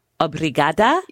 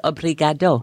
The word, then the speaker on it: Obrigada: Now tell me if I'm saying this right. Obrigada. – Yeah. Yeah. And, you got it right. Yeah. – And now for the gentleman, it's obrigado.